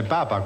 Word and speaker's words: papa: Per 3 milioni, questo nella foto è il latte del papà, giudicato buonissimo. – Del papa, Mike. – Però qua siete voi, cari signori, perché papà si scrive con l'accento, no, credo, sulla A o no? – È papa questo.